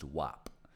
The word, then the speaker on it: WAP: So the acronym is WAP, right? It's been pronounced WAP.